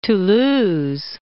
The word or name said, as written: Toulouse